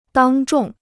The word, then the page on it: 当众: dāng zhòng